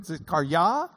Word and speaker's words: Zechariah: Zechariah is the way you would say his name in Hebrew. Zechariah.